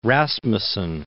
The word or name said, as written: Rasmussen